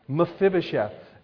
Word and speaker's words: Mephibosheth: Because you can correctly pronounce the name Mephibosheth. Everybody say that. Mephibosheth.